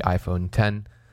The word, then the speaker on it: iPhone X: Last but not least, obviously, the thing that most people were looking forward to was the iPhone 10. That's spelt with an X, Roman numeral X.